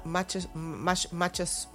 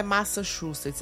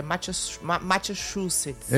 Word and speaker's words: Massachusetts: Lá em Massachusetts. – Abraço, Xandinha, bom dia. – Rose disse que tem que falar Massachusetts. – Massachusetts? – É, Massachusetts. Massachusetts. Ela morou lá, ela disse que não é Massachusetts, é Massachusetts.